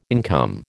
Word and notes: income: American English

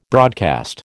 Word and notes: broadcast: American English

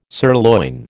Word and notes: sirloin: American English

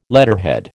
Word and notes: letterhead: American English

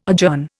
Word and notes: adjourn: Indian

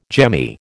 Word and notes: jemmy: American English

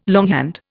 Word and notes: longhand: Indian

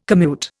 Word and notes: commute: Indian

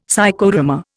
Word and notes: Psychodrama: Indian

